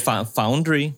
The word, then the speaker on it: Foundry: Pero es Foundry, ya.